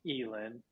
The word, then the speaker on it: Ealan: So my artist name, I mean, it's pronounced Ealan, but it's stylized 3-A-L-A-N, three Ealan.